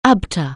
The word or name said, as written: ABTA